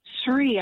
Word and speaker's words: Sri: It's a study program put out by, written by Professor Edward Sree, I think you pronounce it, S-R-I.